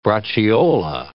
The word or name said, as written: braciola